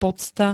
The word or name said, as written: pocta